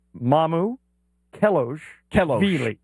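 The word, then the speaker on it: Mamukelashvili: Now, more importantly, can you pronounce Mamu's name with the things I've taught you? – Mamu Keshlevili. – You're close. Mamu Keloshvili. – Keloshvili. Mamu Keloshvili.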